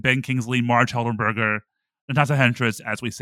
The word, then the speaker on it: Henstridge: Natasha Henstridge, is that how you say her last name? I can never know how to say her last name. Henstridge. – Natasha Henstridge. Henstridge.